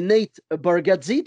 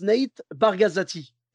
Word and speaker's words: Nate Bargatze: Et donc, lui, son nom, c'est Nate Bargatze, tu vois? Et il y en a, je ne sais pas pourquoi, il prononce Nate Bargazati.